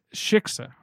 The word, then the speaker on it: Shiksa: And then the shishka comes in. – Shiksa. It's incredible. – I keep saying it wrong.